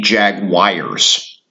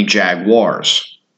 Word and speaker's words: Jaguars: Please do not call them the Jaguars. They are the Jaguars. Much like Star Wars, Star Wars, Jaguars.